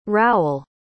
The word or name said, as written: rowell